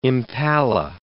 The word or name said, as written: impala